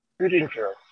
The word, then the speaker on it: Gutinger: How do you pronounce your last name? – Gutinger. – Can you spell that? – Gutinger.